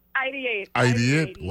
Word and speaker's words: ideate: Ideate or ideate? How do you pronounce it? – Ideate.